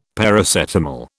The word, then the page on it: Paracetamol: British English